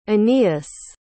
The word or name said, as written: Anius